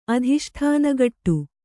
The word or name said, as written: ಅಧಿಷ್ಠಾನಗಟ್ಟು